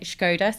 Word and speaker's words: Škoda: Yeah, so we have purposely started pronouncing it Škoda. So obviously we're a Czech company. And when you write the S, it has this little Czech accent over the top, which makes it pronounced Sh.